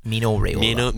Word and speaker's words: Mino Raiola: Mino Raiola.